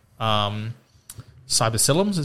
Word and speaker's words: psilocybin: I am all for the microdosing of psilocybins, is that how you say it? – Psilocybin.